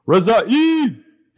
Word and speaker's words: Rezaei: Reza-E. – And how you spell that? – R-E-Z-A-E-I.